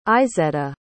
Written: izetta

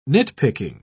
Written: nitpicking

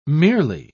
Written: merely

